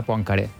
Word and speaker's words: Poincaré: Poincaré, perquè és francès, crec que hauríem de pronunciar Poincaré. Però ja seria d'un esnobisme... – Sí, avales aquesta pronunciació? – Poincaré.